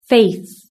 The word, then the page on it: faith: British English